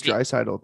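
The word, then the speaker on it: Draisaitl: How do you pronounce Dreisaitl though? – Exactly the way you just said it. – Oh, Dreisaitl.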